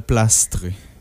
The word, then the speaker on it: Plastrer: Ouais, c'est ça, finalement, on en a convenu qu'il fallait prononcer le S, là, Plastrer.